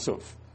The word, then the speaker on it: sough: But some say it's supposed to be pronounced suf. It's spelled S-O-U-G-H.